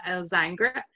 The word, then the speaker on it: Elzingre: So my own name is Tara Elzingre. I always kind of overpronounce the bottom of that because most people get it wrong, but it's my husband's married name.